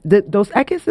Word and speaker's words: Dos Equis: And so, Dos Equis, am I saying it right? – Yeah.